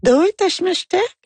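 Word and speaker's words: Doi tashimashite (どういたしまして): » Elle me dit « Qu'est-ce que vous faites ?» Ben j'ai dit « Doi, t'achmechtais ». Elle me dit « Oh non. » J'ai dit quoi? Non, je prononce pas correctement. Elle dit non, il faut dire Doi tashimashite.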